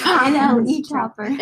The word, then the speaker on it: NLE Choppa: DaBaby, Lil TJ, and Nelly Choppa. – It's NLE Choppa. – NLE Choppa. Choppa. – You said Nelly.